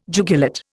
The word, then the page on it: jugulate: Indian